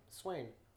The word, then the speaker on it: Swain: Swain. I'm sorry? It's like Wayne with an S.